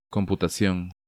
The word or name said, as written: Computacion